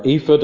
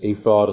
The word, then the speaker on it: ephod: The word ephod, or ephod, I think some people pronounce it. I've grown up hearing it as ephod, and so that has stuck.